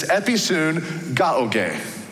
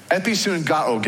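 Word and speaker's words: episunagoge: It has six syllables to it. It's episun gaoge. Episun gaoge is the word, the Greek word for our two English words pressed together, meeting together or assembling together.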